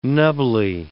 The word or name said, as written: nubbly